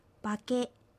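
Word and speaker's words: パケ: バケは頭という意味です。アクセントはケにあります。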